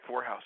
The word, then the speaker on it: Vorhaus: Vorhaus, yep.